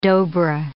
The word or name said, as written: dobra